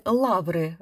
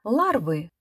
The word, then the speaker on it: ларвы: Друзья искусств, так называемые фармацевты, быстро примкнули к новому веянию и, хотя говорили «лавры» вместо «ларвы» и «недотыкомка» с ударением на втором «о», все же посильно выказывали интерес.